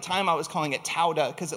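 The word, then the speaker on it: todah (תודה): And this week we're looking at a word in the Hebrew that is pronounced todah. So todah.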